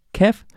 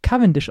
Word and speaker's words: Cavendish: aber er heißt, wird tatsächlich Mark Cavendish ausgesprochen. Die ARD Sportschau hat ihn mal nochmal gefragt, wie er ausgesprochen wird. Er kommt von der Isle of Man und er spricht sich selbst Mark Cavendish aus.